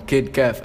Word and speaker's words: Kid Kev: y nada, aquí estamos con Kid Kev.